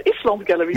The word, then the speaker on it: Island Gallery: Vertel eens over de Island Gallery. – Ja, jij zegt Island Gallery. Ja. Dat vind ik leuk, want dat is eigenlijk ook wel hoe je het moet uitspreken. Want het refereert aan IJsland.